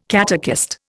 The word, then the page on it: catechist: Indian